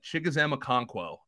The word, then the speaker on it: Chigoziem Okonkwo: Uh, Chigoziem Okonkwo.